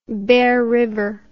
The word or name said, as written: bear river